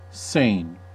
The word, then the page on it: Seine: [sɛn]; France (Île-de-France); French